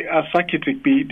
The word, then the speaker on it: Asakitikpi: Okay, Asakitikpi. The KPI is one syllable, just as in rugby.